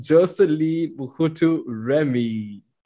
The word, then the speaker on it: Jocelyne Muhutu-Remy: We have Jocelyne Muhutu-Remy.